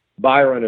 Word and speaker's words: Byron: The easiest way to find me, instead of going to my web address, is Byron is spelled B-Y-R-O-N, and then Craft is just like Arts and Crafts, C-R-A-F-T.